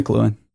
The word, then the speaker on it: McLuhan: McLuhan. Yeah. – What is it? McLuhan? – McLuhan.